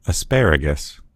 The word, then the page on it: asparagus: American English